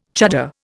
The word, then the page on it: chuddar: Indian